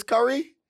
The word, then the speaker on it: Kyree: My brother Kyrie. – Your brother Kyrie. Wait a minute. Your brother's name is Curry? – Yeah. – How does he spell it? – K-Y-R-E-E.